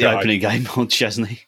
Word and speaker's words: Szczesny: It's Szczesny. – Szczesny, there we go.